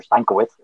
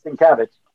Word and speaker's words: Sienkiewicz: My mom dropped me off, and I had a rough meeting with a guy named Bill Sienkiewicz, or Sienkiewicz, as I used to call him. Sienkiewicz! I thought that's how you pronounced it.